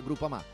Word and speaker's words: Groupama: S-P-O-T. Spot. – Groupama. Ah no, si pronuncia Groupama.